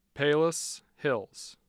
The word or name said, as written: palos hills